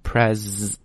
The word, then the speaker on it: presidential: 那读那个 presidential election, 注意这个发音 ,pres, 一定要发 z 这个音。